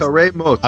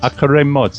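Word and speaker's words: Acharei Mot: Achrei Mot. – Achrei Mot.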